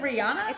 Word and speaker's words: Rihanna: I said it wrong by accident. – Is it Rihanna? – It's Rihanna. – Did you call her Rihanna? – It's not Rihanna.